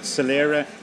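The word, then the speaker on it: soiléire: Right, Solera is, it's a Gaelic-Irish word. It means clarity or clearness, and it's spelled S-O-I-L-E-I-R-E.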